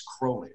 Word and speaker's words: Crowley: It's Crowley, right? – Crowley rhymes with holy. He rhymed it with holy in this poem called "The Beetle."